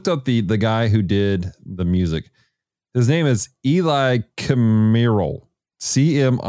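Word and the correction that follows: Cmíral: I apologize for, oh, Smearle. That's how you pronounce it.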